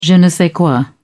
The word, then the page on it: je ne sais quoi: British English